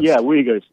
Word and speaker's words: Uyghurs: The Uyghurs, yeah. I think they're it's pronounced. – Uyghurs, yeah, yeah.